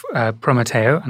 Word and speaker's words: Prometeo: No, you're doing a fantastic job pronouncing that, which is quite Spanish, Prometeo.